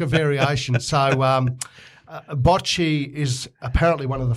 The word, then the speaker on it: bocce: Is it called bocce? – Bocce.